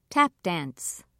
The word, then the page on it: tap dance: American English